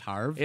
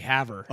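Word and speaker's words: Havre: Is it pronounced Harv? – It's actually Haver.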